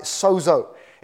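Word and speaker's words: sozo (σῴζω): The word in verse 36 that the onlookers use for cured, the Greek word that the New Testament is written in, is a word that we pronounce in English. We've kind of anglicized it, sozo.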